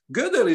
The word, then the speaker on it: Gödel: Gödel is a strange name because it's made of two syllables, which is one, the first one is God, and the second one is El, which are two names for God in two different languages.